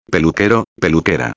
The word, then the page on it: peluquero/a: espanhol